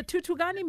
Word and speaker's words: Thuthukani: Ma, the Thuthukani Music Conservatoire.